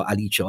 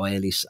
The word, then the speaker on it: ALICE: progetto Alice o Alice, a seconda di usate la pronuncia italiana o inglese,